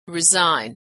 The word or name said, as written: resign